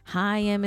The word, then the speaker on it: Haim: I have wronged you all because I mispronounced Haim.